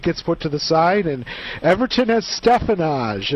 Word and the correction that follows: Stevenage: a very interesting away tie for the top. – Stevenage. – Thank you. I mispronounced it.